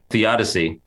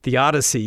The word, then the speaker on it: theodicy: Theodicy. – Theodicy, yeah.